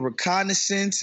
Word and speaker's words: reconnaissance: But another thing I would say, Maze, like, I would help y'all do the reconnaissance. Did I say that right? – Yes.